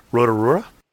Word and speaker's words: Rotorua: and I'm going to say this wrong, Rotorua? – Oh, no, that was a good pronunciation.